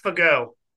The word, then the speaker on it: Fagot: It's spelled F-A-G-O-T. – Fago.